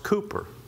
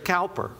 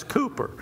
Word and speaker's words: Cowper: That's pronounced Cooper. Okay. Everybody says, who's Mr. Cowper? No, that's Cooper. All right. And if you learn one thing today, we learn how to pronounce his name, Cooper.